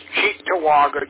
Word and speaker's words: Cheektowaga: It's Cheektowaga, just like as in the cheek on the side of your face, and it's an Indian name meaning land of the crab apple.